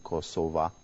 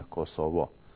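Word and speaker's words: Kosova: الآن الآن نحن نقول نستعمل نحن الألبان نقول كوسوفا. – كوسوفا. – نعم كوسوفا أو كوسوفا. والصرب يقولون كوسوفو. يعني خطأ أن نقول كوسوفو، لكن كوسوفا هذا اسم صحيح.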